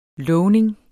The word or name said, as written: LOVNINGS